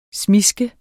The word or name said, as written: smiske